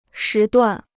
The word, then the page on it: 时段: shí duàn